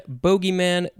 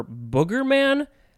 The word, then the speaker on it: bogeyman: I would call it boogeyman. It is spelled bogeyman, B-O-G-E-Y or B-O-G-Y, but you can also, I think, say, boogeyman, bogeyman, boogeyman, or boogerman. I'm pretty sure it's telling me people pronounce it boogerman, which I think is hilarious.